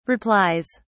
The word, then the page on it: replies: British English